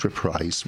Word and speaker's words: reprise: Did I say that right? Reprise or reprise? Reprise. Reprise, reprise.